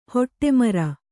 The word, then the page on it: ಹಠಮಾರಿ: kannada